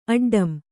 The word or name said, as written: ಅಡ್ಡಂ